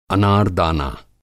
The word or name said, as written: अनार-दाना